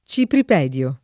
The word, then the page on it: cipripedio: lingua italiana